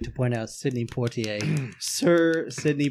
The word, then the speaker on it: Poitier: The director was Sidney Poitier. – Poitier.